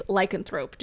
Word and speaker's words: lycanthrope: So another word for werewolf is lycanthrope.